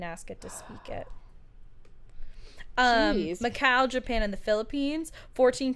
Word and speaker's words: Macau: Is that how you say that? Macaw? I think it's Macaw. No, that's how you say it when it's a bird. – Yeah, Macaw is like M-A-C-A-W. This is a U. I feel like Macau is probably... – Macau.